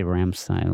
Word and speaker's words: Rammstein: Yeah, I say Rammstein. Like I totally don't know how to pronounce it. – Ram means to ram something, and Stein is a really?